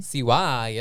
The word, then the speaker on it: Siwa: Is that how you say her name? – Siwa. Yasmin's girl.